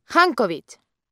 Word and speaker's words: Jancovich: J-A-N-C-O-V-I-C-H. – Jankovic.